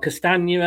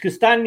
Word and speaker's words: Castagne: I only manage Castagne because I think of lasagna, you know.